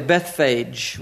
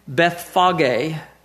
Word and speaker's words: Bethphage: I would say Bethphage, wouldn't you? Bethphage, sort of a Greek pronunciation, and Bethany, at the Mount of Olives, he sendeth forth two of his disciples.